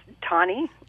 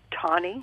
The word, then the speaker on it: Tawni: But right now, let's go to Agora and talk to, and if I'm pronouncing your name wrong, please correct me, it's Tani. – Tani. Right. How do you spell it? I want to make sure Eric spelled it right. – Sure, T-A-W-N-I. – And say it again for me. – Tani.